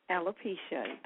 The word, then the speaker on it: alopecia: Did I pronounce it correctly? Alopecia? – Alopecia. That's correct.